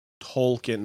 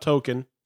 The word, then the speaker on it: Tolkien: Tolkien, not Token. Tolkien. – His name is spelled with an L.